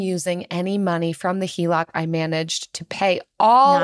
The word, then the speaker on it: HELOC: I recently got approved for a HELOC. Is that how you pronounce it? – That is how you pronounce it.